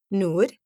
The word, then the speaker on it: Noor: My name is Nora, or if you can pronounce it, Noor.